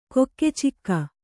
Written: ಕೊಕ್ಕೆಚಿಕ್ಕ

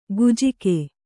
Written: ಗುಜ್ಜಿಗೆ

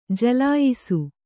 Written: ಜಲಾಯಿಸು

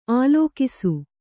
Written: ಆಲೋಕಿಸು

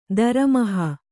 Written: ದರಮಹಾ

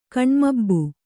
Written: ಕಣ್ಮಬ್ಬು

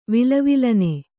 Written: ವಿಲವಿಲನೆ

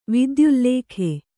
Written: ವಿದ್ಯುಲ್ಲೇಖೆ

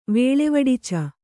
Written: ವೇಳೆವಡಿಚ

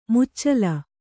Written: ಮುಚ್ಚಲ

